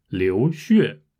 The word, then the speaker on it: 血: 流血，呃，也可以读作是流血。血有两个发音，一个是血，一个是血，两个发音都是对的。